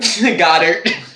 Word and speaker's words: Goedert: Go dirt. Go in the dirt. Goddard? – Yeah. Goddard.